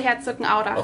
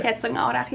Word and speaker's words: Herzogenaurach: It's very close to Herzogenaurach. – Okay, okay. – That's how you say it in German. – Okay. – Herzogenaurach, yeah.